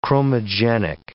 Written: chromogenic

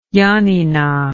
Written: janina

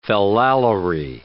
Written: fallalery

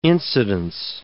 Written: incidence

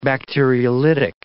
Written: bacteriolytic

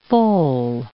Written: foal

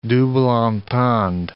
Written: double entendre

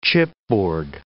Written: chipboard

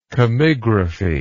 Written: chemigraphically

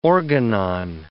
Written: organon

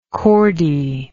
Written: chordee

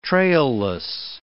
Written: trailless

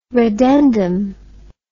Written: reddendum

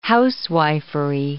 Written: housewifery

